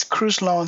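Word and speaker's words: Crus Lon: My artistic name is Cruz Lawn. So it's basically C-R-U-S Lawn, which is L-E-O-N. I mean, I'm sorry, L-O-N.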